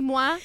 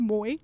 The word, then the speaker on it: Moi: It's Moi, actually, which is even grosser. Like, it should be Moi if you've taken any French. He's also Canadian, but it's pronounced Moi.